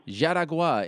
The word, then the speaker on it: Jaraguá: It goes down May 18th, I should say, at Arena Yarugua. How do I say that? – Jaraguá in Jaraguá do Sul, Brazil.